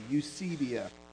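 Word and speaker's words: eusebeia: It's E-U-S-E-B-E-I-A, eusebia.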